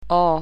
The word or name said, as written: o